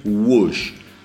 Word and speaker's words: Whoosh: Los Deftones le ponen OMS, que se escribe O-H-M-S. Deep Purple le pone Whoosh, W-H-O-O-S-H, -O sea, OMS, Whoosh ping, pum, cabón.